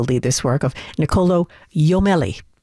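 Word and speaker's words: Jommelli: His last name is spelled with a J, so it looks like Jomelli, but it's actually pronounced Jomelli.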